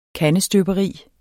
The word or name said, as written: kandestøberi